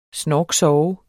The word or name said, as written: snorksove